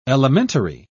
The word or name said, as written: elementary